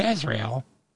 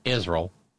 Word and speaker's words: Israel: Because while at least Mullah Mike and his cronies put forward a bill of some sort to support Israel. I'm sorry, I mispronounced it. Israel.